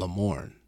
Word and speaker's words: Lamorne: Make sure to follow him on Insta at Lamorne. – That's it. – You got that one name. – It's like Prince. – Right. – It's Lamorne. – Yeah. – Like Beyonce, but it's like Lamorne.